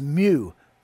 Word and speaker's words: mu: Now the Greek letter, its equivalent is mu. Mu, that's how it's pronounced in Greek, because it comes from the Hebrew word mem.